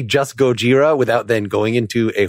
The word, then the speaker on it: Gojira: And on today's show, I'll be insisting on the pronunciation Gojira Consider yourself warned.